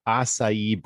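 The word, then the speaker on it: açaí: I think she pronounces it acai bowl.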